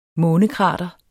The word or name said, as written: månekrater